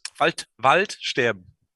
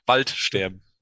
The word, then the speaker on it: Waldsterben: Waldsterben. It's like two words, one word. Wald is forest. And sterben is dying. Waldsterben. That's a perfect German pronunciation.